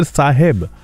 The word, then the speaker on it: साहेब: आप मुझे फॉलो कर सकते हैं, द सिंगर साहेब, SAHEB. जी हाँ, ये साहेब की स्पेलिंग है, नॉट साहब.